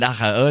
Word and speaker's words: Acha: Aber die gängigsten wie Spotify, Apple Music, Deezer, Soundcloud auf jeden Fall, da findet man nicht unter Acha Ben, also A-C-H. – Acha wird es ausgesprochen, genau. Ich sag die ganze Zeit Acha.